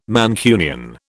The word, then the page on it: Mancunian: British English